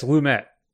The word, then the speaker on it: Lumet: Yeah, it's Lumet. – Okay. Excuse me. – Not even saying it right. I was saying Lumet, but it's Lumet. Lumet.